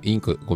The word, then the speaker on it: INQ: それぐらい、インク、はい、インク、ごめんなさいです。聞いてる方に説明すると、株式会社インク、 INQ と書いて、はい、インクという会社の代表が若林さんですね。